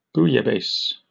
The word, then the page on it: bouillabaisse: British English